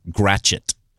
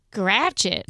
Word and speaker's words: Gratiot: It's actually pronounced here Gratchet. – Gratchet? – Yes.